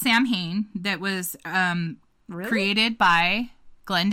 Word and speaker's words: Samhain: In Celtic Irish, it's Samhain. But it's spelled S-A-M-H-A-N because Gaelic is a very unique language.